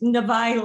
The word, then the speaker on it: Navai: I was going to say it's Navai like Hawaii.